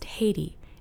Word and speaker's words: Haiti: which is the name of a country pronounced Haiti in English.